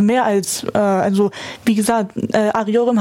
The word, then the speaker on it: arıyorum: Arıyorum.